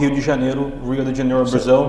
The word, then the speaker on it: Rio de Janeiro: Rio de Janeiro. – Rio, because the R is with the H.